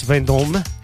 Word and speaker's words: Vendôme: When he went to see it on Place Vendôme. – Place Vendôme. – Yeah, Place Vendôme. – I expect. – A man slapped his... No, it's got an E on the end. Oh, yes, you pronounce the M.